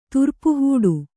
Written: ತುರ್ಪುಹೂಡು

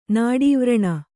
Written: ನಾಡೀವ್ರಣ

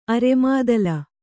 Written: ಅರೆಮಾದಲ

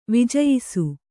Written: ವಿಜಯಿಸು

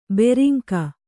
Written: ಭೃಂಗಿ

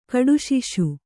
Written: ಕಡುಸಾಸ

